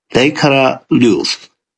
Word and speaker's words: Leikara: But I believe it is pronounced Leikara Ljus. It is two words. The first word is spelt L-E-I-K-A-R-A, so something along the lines of Leikara.